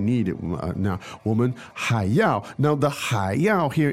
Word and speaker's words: 要: Yao, yao, yao, yao. – Great. And that's the fourth tone, yao.